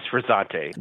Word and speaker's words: Frizzante: Yes, Frizzante. – What are those bars like now that it's not called the Alchemy bar? And can you still get Alchemy drinks there? And then second, what is the Frizzante bar about? – Yep.